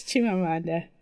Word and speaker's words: Chimamanda: It's Chimamanda.